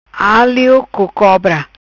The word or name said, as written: Alio co cobra